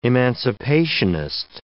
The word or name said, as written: emancipationist